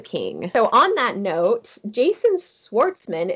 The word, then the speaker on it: Jason Schwartzman: Jason Schwartzman.